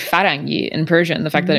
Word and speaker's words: Ferengi: Ferengi.